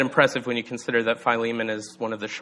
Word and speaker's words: Philemon: Uh, some people pronounce it Philemon. Uh, and I think that that's probably the most correct pronunci- pronunciation, but I'm just going to continue to say Philemon because that's what I grew up saying.